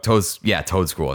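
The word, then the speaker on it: Toedscool: Toadscroll is how you say it.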